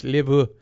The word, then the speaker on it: LaBeouf: And we can also talk about Shia LaBeouf, however you pronounce his name, LaBeef, LaBeouf? – LaBeouf, whatever. – LaBeouf. – LaBeouf. – Let's go French. LaBeouf.